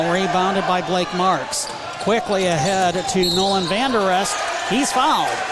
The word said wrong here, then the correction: Van Der Roost: I've been pronouncing his name wrong all night, and I apologize. Nolan Van Der Roost for 1-1.